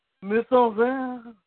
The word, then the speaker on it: mise-en-scène: You are totally mispronouncing that, by the way. – Mise-en-scène? – Mise-en-scène? – Mise-en-scène. 20 years ago. Come on, give me a break.